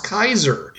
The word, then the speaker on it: Caesar: Caesar is actually pronounced Kaiser.